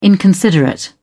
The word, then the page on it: inconsiderate: British English